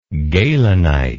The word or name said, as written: gehlenite